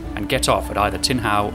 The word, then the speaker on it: Tin Hau: Today we're off to explore the neighbourhood of Tin Hau in Hong Kong, which is pronounced tin like the metal, and how like the question, so how do I get there?